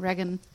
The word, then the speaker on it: Reagan: Reagan, Reagan, Reagan, tut mir leid, falsch ausgesprochen. Reagan.